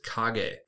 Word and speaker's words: kage: And the Japanese word for shadow is kage. Kage is spelled K-A-G-E.